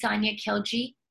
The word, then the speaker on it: Sania Khiljee: It is Social Media School with Sania Khiljee,